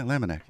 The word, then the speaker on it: Lamniack: If you just tuned in, am I pronouncing your last name right? – Yeah, Lamniack. Yeah, it's pretty obvious. – A lot of people add an I at the end. I'm like, no, it's not Lamniack.